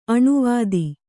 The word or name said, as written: ಅನುವಾದ